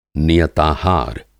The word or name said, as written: नियताहार